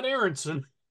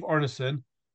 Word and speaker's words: Arneson: Yes, not Aronson, as I No. – have incorrectly pronounced it several times in my life.